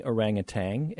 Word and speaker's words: orangutan: I grew up saying orangutan, and I know the other pronunciation, the more correct pronunciation, is without that G sound. There is no G in orangutan.